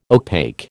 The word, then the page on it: opaque: American English